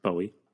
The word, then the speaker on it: buoy: Swims out to a little buoy. Pronounced Bowie.